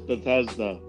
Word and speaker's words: Bethesda: Is it Bethesda? Sorry, Bethesda? – It's Bethesda. Bethesda.